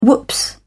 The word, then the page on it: whoops: British English